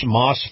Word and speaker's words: Maus: Now, Moss Family Automotive is spelled M-A-U-S, Moss Family Automotive Group.